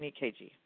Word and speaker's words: EKG: And first line of defense is to do an EKG. Did I say that correctly, Dr. Pandit? – Yes.